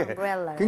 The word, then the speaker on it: umbrella: 네. 럴가 아니고요. 러. – 엄브렐라.